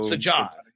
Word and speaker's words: Sajad: Yeah, he said pronounce the J's. So Sajad. And it's not like Spanish. We wouldn't roll the J's. So it's just like a, like almost as if there's one J, I'm guessing, right?